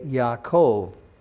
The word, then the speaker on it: Yaakov: In Hebrew, that J sound is really a Y sound. So instead of Jacob, you would say Yaakov. And remember, even in English, uh, an A can be pronounced A or it can be pronounced Ah. In Hebrew, it's Ah.